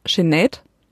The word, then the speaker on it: Sinéad O'Connor: zum Beispiel da in der Medi Mediathek und ich habe vorher immer gesagt Sinead O'Connor, also einfach ist mir das ist mir so über die Lippen gegangen, aber tatsächlich wird sie ausgesprochen Sinead. Das ist so ihr Name Sinead oder Sinead, also ja so halt das lang lang gesprochen.